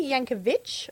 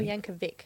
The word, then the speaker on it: Yankovic: Because you are a massive, do you say Yankovic or Yankovic? – Vic. – Okay, because Yankovic is wrong. – Yes, that is not how that man pronounces his name.